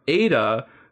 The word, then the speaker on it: Aida: So in case you haven't guessed it, Ada, whose name is spelled A-I-D-A, is an AI robot.